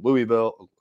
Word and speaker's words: Louisville: It's Louisville. You almost have to make it two syllables. I say Louisville because a lot of people don't know what I'm talking about when I give it the Kentucky twang.